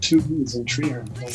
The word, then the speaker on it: Treherne: Nightline was a the CBC radio show, and that's our version of it, called Treherne, or as it's pronounced by the locals in that Manitoba town, Treherne.